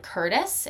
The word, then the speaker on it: Curtice: And the two authors that I hear a lot are Caitlin Curtis, and that's spelled C-U-R-T-I-C-E.